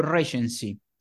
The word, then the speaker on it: recency: R sta per recency, poi la, la, pronuncia me la corregge se dico qualche cavolata. Recency. – Esatto.